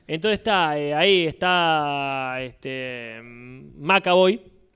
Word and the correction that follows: McAvoy: James McAvoy.